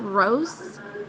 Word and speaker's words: Rose: My first name is spelled Rose, R-O-S-E.